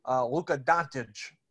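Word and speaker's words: Luka Dončić: Luka Doncic. Is that better? – Yeah, I think that was – okay. – That was about – yeah, I think you were pretty much on there.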